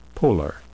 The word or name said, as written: polar